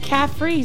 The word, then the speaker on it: Kaffree: They have three major brands, and I kind of already named off two Loma Linda, Neat, and Kaffree Roma. What? And Caffrey sounds like coffee. – Cafe. – Right? Cafe, yeah. It's spelled with a K.